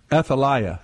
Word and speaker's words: Athaliah: and then and how do you pronounce it? Athaliah. – Athaliah.